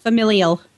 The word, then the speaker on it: familial: How do you say that word? – Familial. – Thank you.